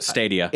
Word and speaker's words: Stadia: Stadia.